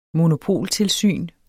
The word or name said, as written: monopoltilsyn